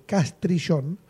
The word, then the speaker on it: Castrillón: lo, lo, pronuncié bien, es Castrillón, ah.